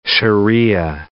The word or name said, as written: sharia